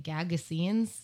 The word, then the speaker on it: Gagosian: That's not how you say it, Gagosian's studio space uh, below his home in Venice, California.